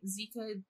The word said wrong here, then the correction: Zakiya: Dahlia Harris? – I want to say Zakiya. – There we go. Yeah. Zakiya Dahlia Harris.